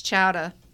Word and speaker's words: chowder: It's chowda. – Okay, with the heavy A? Okay. – Yeah, that's how they say it.